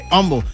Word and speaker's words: Humble: Humble. Ya me corrigieron, no se dice Humble, se dice Humble.